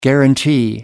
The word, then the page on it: guarantee: American English